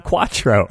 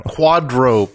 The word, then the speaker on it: Quadro: And once they have her, they change their name to Quattro Pink, except it's not Quattro. – Quadro Pink. – It's spelled Quadro.